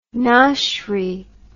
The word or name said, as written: noshery